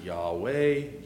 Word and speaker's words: Yahweh: Now, you will find people will pronounce it Yahweh.